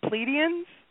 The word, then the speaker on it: Pleiadians: you said it was the Pleiadians? Am I saying that right? I'm not saying it right. – It goes either way. It's, you know, English translations.